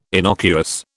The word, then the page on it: innocuous: British English